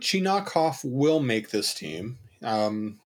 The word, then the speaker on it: Chinakhov: Chinakhov. I've been saying Chinnikov.